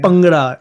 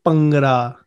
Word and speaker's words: Bhangra: So your background, I believe, is in recruitment, but also professional Bhangra dancing. – Hold on. You're Pakistani and you can't even pronounce it properly. – Bhangra dancing. Yeah. – Bhangra. BH is a buh sound. It's Bhangra.